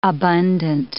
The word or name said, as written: abundant